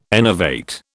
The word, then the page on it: enervate: British English